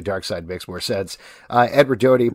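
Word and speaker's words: Darkseid: Dark Side, people say Dark Seed. – Yeah. – Or you've been one of you. – I always did that. – You said Dark Seed.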